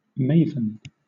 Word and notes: maven: British English

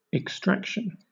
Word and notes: extraction: British English

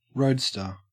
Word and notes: roadster: Australian English

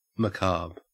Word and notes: macabre: Australian English

